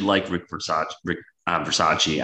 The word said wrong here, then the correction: Bisaccia: I don't think he should be necessarily Rick Bisaccia.